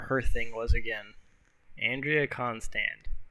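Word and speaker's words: Constand: The main woman behind all this was a woman named Andrea Konstad. Konstand, that's how you say it.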